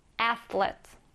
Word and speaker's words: athlete: 발음이 다소 어려울 수 있는데 한번 천천히 따라 읽어볼게요. athlete. 한번 더. athlete. 네, 잘하셨어요. TH 발음이 있어서 조금 어려웠을 것 같아요.